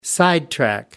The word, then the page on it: sidetrack: American English